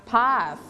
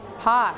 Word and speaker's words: path: don't forget to pronounce TH sound เสียงเธอเธอเธอเราต้องเอาลิ้นไว้แตะไว้ที่หลังฟันอะไรคะฟันล่างหรือฟันบนฟันบนนะคะ Path ตรงนี้ Path That's right Okay Good เดี๋ยวให้นักเรียนออกเสียงเองก่อนคำแรกนะ Number one Good job Next one Balcony.